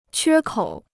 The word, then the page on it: 缺口: quē kǒu